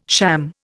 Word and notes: sham: Indian

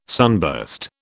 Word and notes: sunburst: British English

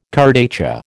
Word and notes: kurdaitcha: American English

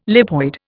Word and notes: lipoid: Indian

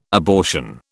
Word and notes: abortion: British English

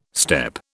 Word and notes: steppe: British English

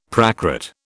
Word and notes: Prakrit: British English

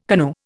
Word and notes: canoe: Indian

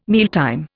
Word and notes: mealtime: Indian

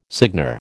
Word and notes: seigneur: American English

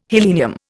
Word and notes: helenium: Indian

